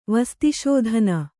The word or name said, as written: ವಸ್ತಿಶೋಧನ